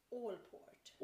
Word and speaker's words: Allport: kan, kan vi uttala hans namn lite långsammare? – Allport. – Allport. Är det A LL. – Ja, jag tror det. A-L-L-P-O-R-T.